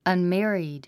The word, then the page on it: unmarried: ʌnmǽrid; アンメリード